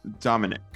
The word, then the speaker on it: Dominic: It's Dom and then like an apostrophe and then ick. Domic. – Dominic. – It's pronounced Dominic. – Sure.